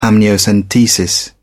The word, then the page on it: amniocentesis: British English